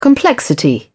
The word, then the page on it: complexity: British English